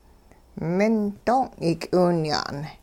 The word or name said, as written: ming doong' yik'iwinya'n